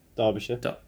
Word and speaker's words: Derbyshire: Derbyshire.